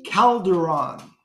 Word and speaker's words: Calderon: And Glenn, just for, you know, my edification, how do I say your last name? – Calderon. – It's Calderon. – Calderon, yes.